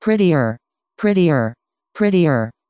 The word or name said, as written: prettier